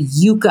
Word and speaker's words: Yuka: I believe it's pronounced the Yuka app, Y-U-K-A.